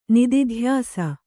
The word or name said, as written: ನಿದಿಧ್ಯಾಸ